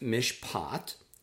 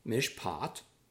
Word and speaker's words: mishpat: The next word is one we've seen earlier, number 5, meaning justice, and that's pronounced mishpat, mishpat.